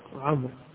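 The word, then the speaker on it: عمرو: الواو تكتب ولا تنطق، تنطق عمر، إشارة للتفرقة بينه وبين عمر، فهي اختلاف الحركات فقط، وإلا كل منهما ليس فيه واو، عمر وعمر كلها راء ساكنة يوقف عليها بالراء الساكنة، لكن الواو للتفرقة بين عمر وعمر.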